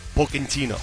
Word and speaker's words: Pochettino: Now, if he was truly Italian and not Argentinian, how would you say his last name? – Pochettino. – Pochettino, correct. Because the CHs are like Ks in Italian.